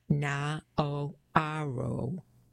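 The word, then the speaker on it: Naoero: What was known to the world for many years as Nauru, Adiang said, was returning to the name and pronunciation of its past. Na-o-a-ro.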